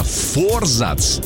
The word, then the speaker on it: форзац: Так вот, действительно, форзац считается правильным ударением.